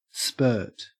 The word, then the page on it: spurt: Australian English